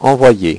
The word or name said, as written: envoyer